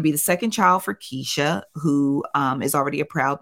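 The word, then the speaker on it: Keshia: It's Keisha. – I know it's Keisha, but I hear you say Kashia. – It's Keisha, but I have to say Kashia or else I will spell it wrong.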